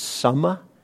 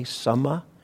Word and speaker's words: Samma: In the Eightfold Path, it's Samma, with a short A, S-A-M-M-A, Samma.